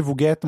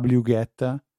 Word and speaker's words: wget: che non si dice wget ma si dice wget. – Vabbè, sicuro. – Perché, perché è double you get.